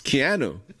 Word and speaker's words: Keanu: And, you know, and I always, I always mispronounce this guy's name, but. – Who? – Keanu? – Keanu. It's Keanu. Keanu Reeves.